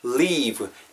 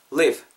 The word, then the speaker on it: leave: leave, L-E-A-V-E 여러분하고 오늘 문장에서 제일 그 발음적인 측면에서도 중요한 부분입니다. 왜냐면은 짧게 발음하시면은 live, live 이렇게 하면은 어, 상대방이 들었을 때 영어권 사람들이 들었을 때 live, L-I-V-E로 알아들을 수가 있다라는 거죠. live, live는 여러분 그뭐 살다라는 뉘스가 돼버리잖아요. 그러니까 오늘은 leave, L-E-A-V-E e.